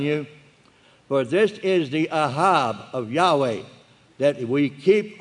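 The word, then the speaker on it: ahab (אהב): But it's ahab, aleph, hey, bet. Pronounce A-H-A-B with low pronunciation, ahab. It's not hard to do at all. Ahab.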